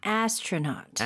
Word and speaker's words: astronaut: Astronaut. Astronaut. AU 뒤에 부분 AU는요, not가 아니죠, 발음할 때. – 네, not입니다. – Okay. – 네, 저번에 tie the knot 배웠잖아요. 그거하고 비슷해요. Astronaut.